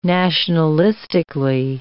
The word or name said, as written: nationalistically